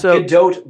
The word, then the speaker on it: Gadot: It's pronounced Gadot, by the way, I heard, because it's Israeli, it's not French.